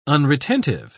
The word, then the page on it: unretentive: British English